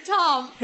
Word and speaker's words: Thom: But it's spelled T-H-O-M. – No, it's spelled T-H-O-M. – So it's interesting. – Right, the H is silent.